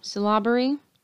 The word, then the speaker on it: syllabary: The Cherokee speak a Southern Iroquoian language that is written in syllabary. – Yeah, you're on your own. – Syllabary. It's like syllables, but A-R-Y at the end.